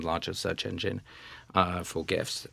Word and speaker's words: GIF: What the correct pronunciation is? – Is GIFs. – Okay. – It's absolutely GIFs.